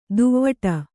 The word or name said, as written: ದೂವ್ವಾಡ